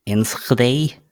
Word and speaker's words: Enschede: Enschede.